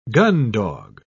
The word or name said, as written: gundog